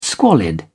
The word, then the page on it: squalid: British English